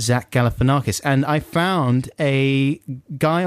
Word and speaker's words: Galifianakis: Zach Galifianakis. – Yeah, all right. – Zach Galifianakis. – Yeah, okay, I get Zach Galifianakis. – All right, I get the message. It's Zach Galifianakis.